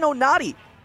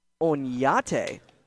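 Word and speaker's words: Oñate: And, well, we actually found out earlier today when me and Fletcher, the TV broadcaster, that, well, there's actually an Enye in his name. And so the official pronunciation is Onyate, as this pitch is a little outside for ball number one. He says the real pronunciation is Onyate, even though he likes people saying Onati when they're trying to pronounce it without the Enye.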